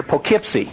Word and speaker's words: Poughkeepsie: I said, Allison, I know that's how it looks, but they pronounce it around here, Poughkeepsie.